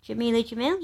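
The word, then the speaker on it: Jameela Jamil: And other women, influential women on the front cover, you know, they go from Jamelia Jamil. Is that how you pronounce her name? Jamelia Jamil, yeah. – I think you've nailed it.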